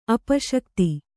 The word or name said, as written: ಅಪಶಕ್ತಿ